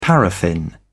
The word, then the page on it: paraffin: British English